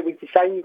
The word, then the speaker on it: WikiSign: Alors, je vais prononcer WikiSign parce que ça s'orthographie en anglais, W-I-K-I-S-I-G-N.